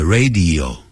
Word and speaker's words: radio: 那么这些词啊通常是跟 on 并用 I heard you on the radio last night 昨晚我在收音机上听到你的声音 I heard you on the radio 那个 radio 不要念成 radio 更不可能念成 radio Radio 是谁说的呢？又是日本人说的。啊，那念成明明也是念的 radio，从他们日本人说这个嘴巴中念成 radio，啊，这样那就不对了。